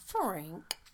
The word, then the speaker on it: Ferenc: F-E-R-E-N-C. But when they pronounce it, like the Hungarian people, they say it's like Frank. – Frank.